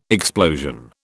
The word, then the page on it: explosion: British English